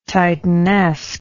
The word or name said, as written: titanesque